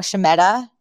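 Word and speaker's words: shmata: We had Shemeta. Why am I saying it wrong? Shemata, which is rags or old ragged pieces of clothing or garments.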